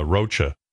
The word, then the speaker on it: Rocha: Yeah, this guy, Rocha. – Rocha. – That's how you say it. Della Rocha. – It's Rocha? – Yeah.